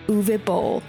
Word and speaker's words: Uwe Boll: Uwe Boll. Yes, apparently that is how you pronounce it, and yes, I did have to look it up.